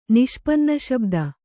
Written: ನಿಷ್ಪನ್ನಶಬ್ದ

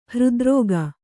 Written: ಹೃದ್ರೋಗಿ

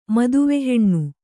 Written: ಮತಿವಿಹೀನ

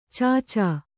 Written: ಚಚು

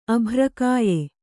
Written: ಅಭ್ರಕಾಯೆ

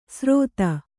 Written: ಸ್ರೋತ

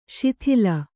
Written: ಶಾತಲ

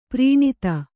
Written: ಪ್ರೀಣಿತ

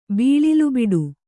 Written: ಬಿಳಲುಬಿಡು

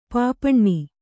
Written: ಪಾಪಣ್ಣಿ

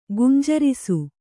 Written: ಗುಂಜರಿಸು

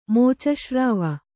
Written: ಮೋಚಶ್ರಾವ

